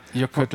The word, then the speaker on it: Jökull: Jökull heißt der, aber das weiß ich noch. Doppel-L, es wird immer T-L ausgesprochen. – Okay. – Ja, Jökull, ja.